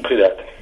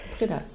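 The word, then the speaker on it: Priddat: Ja, da sind ja dann auch herauszugreifen die Autoren wie zum Beispiel Jacques Poulain oder auch Birger Pridat. Pridat? Wie spricht man es denn? – Pridat. – Pridat, ja. Und Franz Martin Wimmer, Serge Krusinski.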